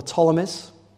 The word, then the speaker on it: Ptolemies: In the south, in Egypt, Alexander's former empire was controlled by some people called the Ptolemies, beginning with a P. Don't know, it's a silent P, Ptolemies.